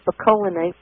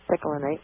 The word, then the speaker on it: picolinate: I pronounced it picolinate, but somebody corrected me that said it's picolinate.